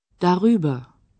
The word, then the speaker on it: darüber: Між да та über вставляється «р». Так слово краще вимовляти. Darüber.